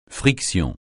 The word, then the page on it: friction: francais